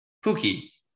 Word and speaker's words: Pukki: Yeah, if you watch Premier League, I thought it was Piku. – Pukki. He plays on Norwich. – Who's a Piku? – Pukki. P-U-K-K-I. – Oh, I thought it was P-E-E-K-U. – No. Pukki.